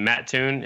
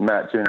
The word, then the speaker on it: Mattoon: So you guys, are in Mattoon? Is that how you say it? Mattoon, Illinois? – Mm-hmm. Mattoon, Illinois, yeah.